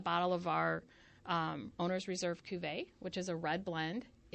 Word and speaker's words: Cuvée: Am I saying it correctly? The Owner's Reserve Cuvée? – Yes.